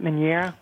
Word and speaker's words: meunière: Meunière? – Meunière, uh-huh.